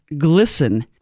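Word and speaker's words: GLSEN: Euphemistically pronounced glisten.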